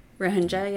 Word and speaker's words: Rohingya: Is that, is that how you pronounce it? In Myanmar, there was always Rohingya, yeah. – Rohingya, yeah.